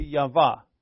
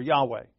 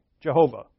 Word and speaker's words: Yahweh: And then he says, let's have a festival to Yahweh, or Yahweh, Jehovah, whatever. Jehovah is the wrong pronunciation, we know for sure.